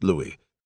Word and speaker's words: Louis: often mistaken for a long-haired Chihuahua named Louis, with a silent S in the French manner, after the Dustin Hoffman character in the movie of the same name.